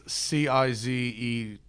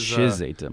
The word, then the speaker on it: Cizeta: Tim, it's Cizeta V16. Which is Chizeta, my mistake. – I'm sorry to pull a pronunciation on you there.